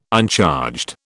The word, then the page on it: uncharged: British English